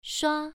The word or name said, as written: shua1